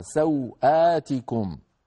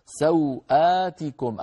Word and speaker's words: سوآتكم: أيضا الكلمة التي بعدها سوآتكم، سوآتكم أيضا بعد الهمزة، يعني ما في ألف طويلة لكن لا بد منها، لا بد من نطقها، لذلك زادها علماؤنا ألف صغيرة يسميها الخطاطون الألف الخنجرية.